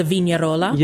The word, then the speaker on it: vignarola: How do you say that term? – The vignarola.